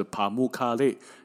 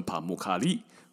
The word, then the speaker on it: Pamukkale: 棉堡的发音啊，不不管是在英文还是土耳其文呢，它的发音大概就是帕木卡列，有人说成帕木卡利哦，反正帕木卡列呢这样子的一个意思哦，帕姆卡雷这样子的大约的发音意思就是棉花的城堡。